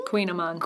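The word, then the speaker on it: Kouign-amann: Queen Amman. – Queen Amman. Thank you for that. It's spelled K-O-U-I-G-N, so I feel like I'm okay with mispronouncing it.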